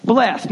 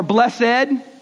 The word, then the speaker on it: blessed: Blessed. Now, we don't use that word, but this is the word they would have used, or blessed, right? Blessed or blessed, depending on how you say it.